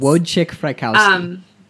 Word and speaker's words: Wojciech Frykowski: Wojciech Frykowski.